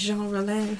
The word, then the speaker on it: Jean Rollin: Jean Rollin, is that how it's pronounced? – It is Jean Rollin.